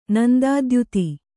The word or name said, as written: ನಂದಾದ್ಯುತಿ